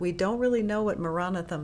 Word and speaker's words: Maranatha: So when we say it, when we're meditating, we say it as four syllables, and we say Ma Ra Na Tha. Ma Ra Na Tha.